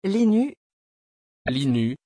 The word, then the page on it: Lynus: French